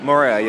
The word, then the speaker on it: Moorea: So tell me about Moorea, is how you say it? – Moorea, yes. So Moorea has, it's known for its lush green mountain peaks and some their beautiful bays.